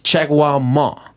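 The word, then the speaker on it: Jagwar Ma: Als Künstler nennen sie sich Jaguar Ma. Mhm. Jaguar nicht wie der Tiger, sondern mit einem W hinterm G. Spricht man aber dann trotzdem gleich aus.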